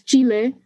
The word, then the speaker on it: Chile: Am I saying it correctly? Is it Chile? – It's Chile. Mm-hmm. I kind of like do a middle of like English.